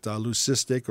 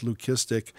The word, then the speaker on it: leucistic: This, again, is that leucistic or sometimes pronounced leukistic.